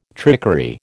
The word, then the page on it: trickery: American English